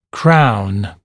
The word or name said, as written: crown